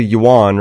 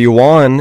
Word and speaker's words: yuan: The dollar to the yuan, right? Am I saying that right? – That's correct. – The yuan, it's 7-1, or I guess 1-7 dollar to yuan.